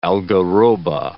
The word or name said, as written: algaroba